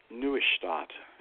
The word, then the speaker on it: Neustadt: There's a fellow by the name of Richard Neustadt, and that's the German pronunciation because I kind of lived there for a long time. I got in a little debate with one of my professors of that time, and he said it's just simply Neustadt, but it's Neustadt. That's how it's pronounced because of the way it's spelled, and it's German.